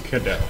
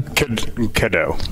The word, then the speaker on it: Cadeau: And Chris, is it Cadro? Did I say that correctly? – Cadeau. – Oh, a cadet. How do you say it again? – Cadeau. – Cadeau. Cadeau. Okay. You're French. – It's tough. It's French.